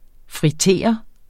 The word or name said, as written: fritere